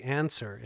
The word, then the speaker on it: Anser: Now, for all you tech heads out there, the Answer, it's spelled A-N-S-E-R, the Answer preamp allows you to add plus 25 decibels of clean, transparent gain to your SM7B right from the microphone itself.